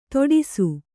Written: ತೋಡಿಸು

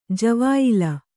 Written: ಜವಾಯಿಲ

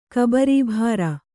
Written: ಕಬರೀಭಾರ

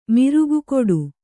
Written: ಮಿರುಗುಕೊಡು

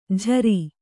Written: ಝರಿ

